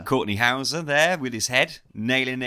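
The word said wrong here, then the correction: Hause: Hawes. Courtney Hawes. – Is it actually Hawes? – Hawes. Yeah. – Like the animal.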